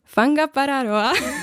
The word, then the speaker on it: Whangaparaoa: Já jsem doletěla tedy do Aucklandu a potom vlastně to dobrovolničení bylo na poloostrově Whangaparaoa. Myslím si, že takhle je správná výslovnost. Je to vlastně maorský název a to je asi plus minus hodinu od Aucklandu.